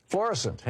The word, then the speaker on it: Florissant: Florissant.